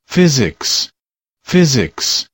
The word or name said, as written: physics